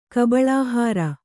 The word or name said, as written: ಕಬಳಾಹಾರ